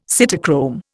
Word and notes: cytochrome: Indian

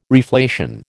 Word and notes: reflation: American English

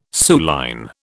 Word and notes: suilline: British English

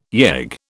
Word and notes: yegg: British English

